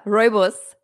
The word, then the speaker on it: Rooibos: And I'm I am now a huge uh, how do you say Rubios fan. yeah, Rooibos. – Rooibos.